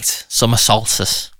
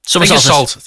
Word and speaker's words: Summer Solstice: Do I say that right? Summer Solstice. – No, Summer Solstice. – Oh, you see, I just say it really fast and get away with it. – Summer Solstice. Think of salt.